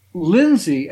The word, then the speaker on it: Lindsy: Um, my name is Robert Lindsy Milne, and Lindsy, L I N, the way my name is spelled, L I N D S Y, is very, very unusual.